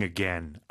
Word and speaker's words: again: A-G-A-I-N. – 네, 이 단어는 again으로 잘못 발음하는 경우를 많이 봤는데요. Again, 짧게 에 발음으로 발음해 주세요.